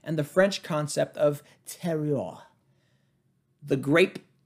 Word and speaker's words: terroir: No, it's more like terroir. I think it's kind of spelled like terroir. Terroir. It's T-E-R-R-O-I-R. I know the two R's are kind of fluid. And then the O-I-R, it's oi. Terroir. O-I-R.